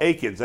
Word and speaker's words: Akins: Is it Akins or Atkins? I always pronounce it wrong. – Akins. I think it's Akins.